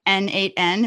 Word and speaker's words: n8n: And you are the head of developer relations at N8N. Am I saying that correctly? – That's correct. Yeah.